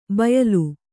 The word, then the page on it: ಬಯಲು: bayalu